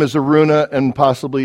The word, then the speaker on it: Araunah: You pronounced it very well, brother. In fact, the English pronunciation is no different from the Hebrew pronunciation, Araunah.